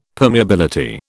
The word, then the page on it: permeability: British English